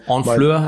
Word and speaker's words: Honfleur: Honfleur heißt es? – Genau, das H spricht man nicht mit aus, aber es fängt mit H an.